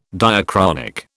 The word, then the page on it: diachronic: British English